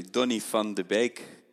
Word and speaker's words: Donny van de Beek: Can you give me the definitive pronunciation of Donny van de Beek? – Yeah, that's that's completely right. Actually, Donny van de Beek. Donny van de Beek. Uh, yeah, that's it. That's exactly it.